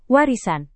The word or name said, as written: warisan